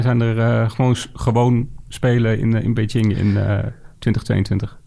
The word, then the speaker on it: Beijing: Je hebt natuurlijk al de Olympische Spelen, zeg maar, Beijing. Zeg ik dat goed? Zeg maar, ja, volgens mij is het Beijing.